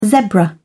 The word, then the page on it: zebra: British English